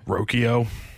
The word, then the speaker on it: Rocchio: Because he pronounces it differently. And how does he pronounce it? – Rocchio.